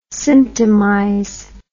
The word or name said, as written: symptomize